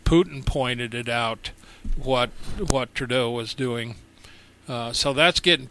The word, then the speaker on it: Trudeau: Trudeau.